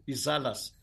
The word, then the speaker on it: Izala: Just to interrupt you for a second, for our listeners, just so everyone understands, I'm having a hard time pronouncing the word, the Nsalas are Shia. – Okay, Izalas.